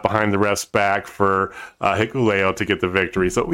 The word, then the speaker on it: Hikuleo: Chris Bay and Hakuleo. Oh god, I can't pronounce it. Hikuleo. – Hikuleo.